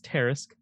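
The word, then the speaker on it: Tarasque: If you thought a $400 Terrisk, which I've discovered it is pronounced Terrisk. – It is pronounced Terrisk. Okay.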